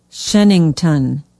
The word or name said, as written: Shennington